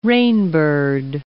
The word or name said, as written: rainbird